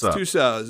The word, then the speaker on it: Tussauds: It's Tussauds. It's if you. There's a pronunciation guide, and it's Madame Tussauds of Las Vegas. Yes, for some reason it was of Las Vegas, but the version we found. But it is Tussauds.